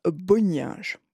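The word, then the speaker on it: Bonynge: sous la direction de Richard Bonin. C'est le fameux de 1962 dont... Chacun a sa prononciation, en fait. Moi, j'aime bien dire Boninge.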